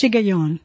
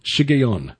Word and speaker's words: Shiggaion: And this is referred to as and I don't even know how to pronounce this, S-H-I-G-G-A-I-O-N. – I think it's Shigayon. – Shigayon. And we believe,